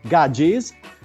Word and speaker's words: gadgie: E Gaggi però è uguale anche in inglese, perché si scrive G-A-D-G-I-E, Gaggis.